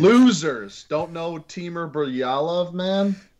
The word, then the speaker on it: Bilyalov: Oh, Timur Bilyalov. – Yeah, that sounds better than Bilyalov. – Jake, here clearly, yeah, that's a little better than my attempt.